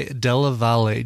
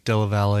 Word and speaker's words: Della Valle: Send Me an Angel, originally performed by Real Life in the 80s, covered there by Della Valle, J. Della Valle, and I hope I'm pronouncing that last name right. It's D-E-L-L-A-V-A-L-L-E. Maybe it's Della Valle? No, I think Della Valle if it's two L's.